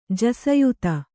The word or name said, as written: ಜಸಯುತ